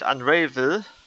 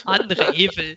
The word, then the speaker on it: Unravel: Wir sind in Deutschland hier, da spricht man Deutsch aus. – Richtig. – Unravel. – Unravel. Ich spreche meinen Helden immer so aus. – Genau. Wie beim Rave.